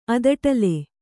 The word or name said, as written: ಅದಟಲೆ